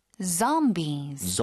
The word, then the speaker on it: zombies: 자, 좀비 발음 좀 도와주세요. – Zombies. – 저에 가깝죠. 네. – Zombies.